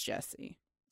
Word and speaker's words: Gyasi: And we are reading, drum roll, Homegoing by Yaa Jessie. And it looks like Gyasi, but it's pronounced Jessie.